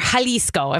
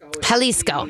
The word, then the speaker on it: Jalisco: Obviously, I'm going to talk a couple things to kind of let you understand that tequila has to come from Jalisco, or Jalisco, if I'm going to say it correctly. I'm sure Melissa would, like, smack me. – Jalisco.